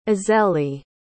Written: azélie

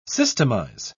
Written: systemize

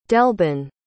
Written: delbin

